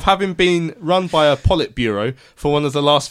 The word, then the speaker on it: Politburo: so, right, and the word appears to be Politburo, and now, out of because uh, I wanted to check how you pronounce it, I, have got it here. So here we go. Politburo. Politburo.